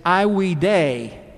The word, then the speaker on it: Ai Weidei: And she became to be known as Ai Weidei, which is actually the correct pronunciation of the name that she was called, meaning virtuous one, by the Chinese who gave her that name.